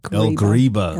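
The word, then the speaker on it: El Ghriba: On the 11th of April, a natural gas truck fitted with explosives drove past security barriers at the ancient El Gibra? – Greba. El Greba.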